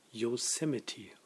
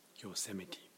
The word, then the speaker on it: Yosemite: Der Name des neuen OS X jedenfalls kommt aus dem Indianischen und wird irritierenderweise Yosemite ausgesprochen. Yosemite. Yosemite. Ich höre euch ungefähr seit Mitte der 30er Folgen, allerdings meistens mit zeitlicher Verzögerung.